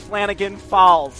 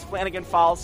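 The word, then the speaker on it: Flanagan-Falls: Demetrius Flanagan Falls sound like falls. So that's actually one whole name, because in the pronunciation guide, there's no space. So it's Demetrius Flanagan Falls sound like falls.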